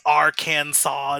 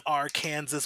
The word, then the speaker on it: Arkansas: Just like how it's Arkansas instead of Arkansas. What's an Arkansas? There's no W in there.